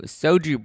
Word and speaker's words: Xoju: I attended a fun launch party for uh, this new soju brand, which is actually called soju, but it's spelled X O J U.